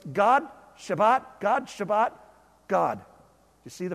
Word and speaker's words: Shabbat: So this vayishabbat, that's Shabbat. I'm going to talk about that word in a minute. That's how you say it, by the way. In Hebrew, it's an SH sound and a hard T.